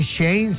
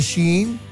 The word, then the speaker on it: Shein: Y que le dijeron, oye, es que no se dice Shane, se dice Shein, o sea, es in Shein.